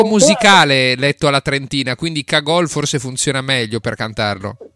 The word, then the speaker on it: Cagol: Comunque si dice Cagol. Comunque si dice Cagol perché lei è di Sopramonte, era di Sopramonte vicino Trento, e il cognome Cagol si legge Cagol, non Cagol.